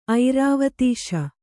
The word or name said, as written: ಐರಾವತೀಶ